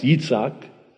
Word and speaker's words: Yitzhak: In Hebrew, it's pronounced Yitzhak.